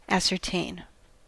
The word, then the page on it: ascertain: American English